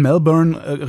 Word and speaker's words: Melbourne: Wie spricht man Melbourne richtig aus? – Melbourne.